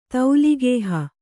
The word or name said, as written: ತೌಲಿಗೇಹ